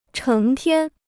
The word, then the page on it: 成天: chéng tiān